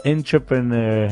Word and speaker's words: entrepreneur: Entrepreneur. É, é difícil. – Entrepreneur. – É, viu? É entrepreneur, não é entrepreneur.